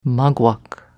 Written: Maguak

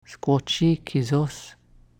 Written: Squochee kesos

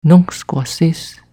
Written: Nuncksquassis

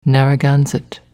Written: Narragansett

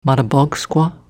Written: Mattabaugsqua

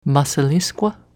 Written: Massaliskwa

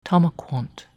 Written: Tamakwont

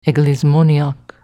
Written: Englismoniak

